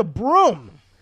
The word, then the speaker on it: Bruttenholm: It turns out his name is like the Welsh spelling and pronunciation of it. It's actually spelled Bruttenholm. And I remember seeing the movie initially, I was like, why the fuck did they change his name to Broom?